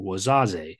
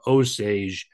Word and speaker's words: Osage: While I'm not a French speaker, I understand that they probably use letters like O-U-A-S-A-G-E, which in French would be pronounced closer to Wazazi. When English speakers saw it, they pronounced it in English, simplified it to Osage, and well, there you go.